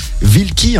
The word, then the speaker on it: Vilky: Alors toi qu'on connaissait Solitaire en production, tu signes cette fois ce single avec un autre producteur, Vilky, hein, on prononce comme ça, hein, c'est ça.